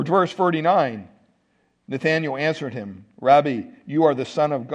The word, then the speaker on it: Rabbi: And they said, Rabbi or Rabbi, however you want to pronounce it. Rabbi is the way it's pronounced in the Greek, which means teacher.